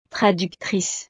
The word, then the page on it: traductrice: frances